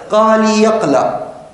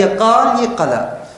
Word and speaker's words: قالي قلا: أي خاطئة، هو نطقها معدي كرب بإسكان الياء اللي مثل هذه، مثل قالي قلا، بعضهم ينطقها قالي يقلا، لا هي قالي قلا.